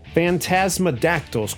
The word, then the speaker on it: Phantasmadactyls: Phantasmadactyls, correct? – You said it right. – Flight of the Phantasmadactyls.